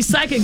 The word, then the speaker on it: psychic: So you're right, because when we see psychic, it's spelled P-S-Y, right? – I couldn't tell you. – Yes, yeah. This, yep. – So the P is silent.